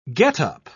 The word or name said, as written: getup